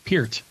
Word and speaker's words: Peart: Peart.